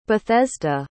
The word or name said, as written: Bethesda